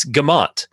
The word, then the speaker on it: Guimont: It is not Guimont or Guimont, as it would be if it's in French, but it's Gamont. The UI is silent.